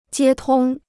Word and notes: 接通: jiē tōng

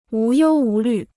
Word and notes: 无忧无虑: wú yōu wú lǜ

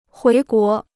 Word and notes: 回国: huí guó

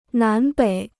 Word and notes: 南北: nán běi

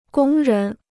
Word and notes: 工人: gōng rén

